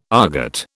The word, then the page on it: aargot: British English